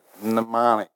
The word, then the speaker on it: mnemonic: It starts with an M and an N, mnemonic. How do you say that, mnemonic, but the M is silent?